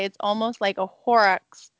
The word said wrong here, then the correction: Horcrux: Am I saying that right? – What? – Horcrux. – Horcrux. – Oh. – Okay. – From Harry Potter.